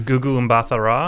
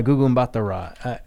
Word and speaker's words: Gugu Mbatha-Raw: Gugu Mbatha-Raw? – Yeah, Gugu Mbatha-Raw.